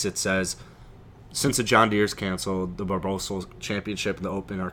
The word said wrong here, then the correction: Barbasol: How did you pronounce Barbasol? Barbosol? – Barbosol? – Barbasol is like it's one like of the biggest shaving cream. – It's like barbacoa at Chipotle. – It's pronounced Barbasol.